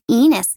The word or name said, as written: Ines